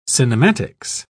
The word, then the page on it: cinematics: British English